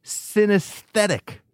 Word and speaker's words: synesthetic: The word is synesthetic. Did I say it right? Yes, you did.